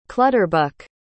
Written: clutterbuck

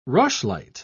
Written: rushlight

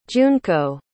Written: junko

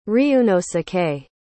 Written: ryuunosuke

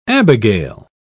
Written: abigail

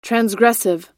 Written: transgressive

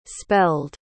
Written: spelled

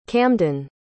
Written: camdan

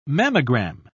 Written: mammogram

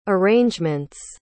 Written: arrangements

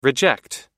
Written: reject

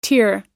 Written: tier

